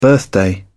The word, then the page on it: birthday: British English